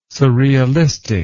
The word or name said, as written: surrealistic